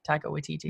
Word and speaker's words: Taika Waititi: Taika Waititi? – Yeah.